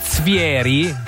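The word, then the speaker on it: Zvieri: Svieri, si legge così, no? Svieri, perché la V e la F, no? In tedesco.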